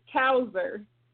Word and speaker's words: Cousar: It's Cousar.